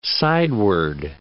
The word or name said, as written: sideward